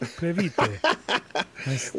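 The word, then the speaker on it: Previte: I'm sure that's Previtte. How you really pronounce it. Previtte.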